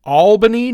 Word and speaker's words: Albany: Probably going to swing into Albany, New York.